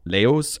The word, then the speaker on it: Laos: Laos. Laos. – Laos. – Laos. Yeah. Laos.